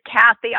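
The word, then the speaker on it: Cathy: So my website is Kathy. I'll just spell it because it is a long, complicated name. It's C-A-T-H-Y.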